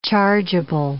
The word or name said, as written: chargeable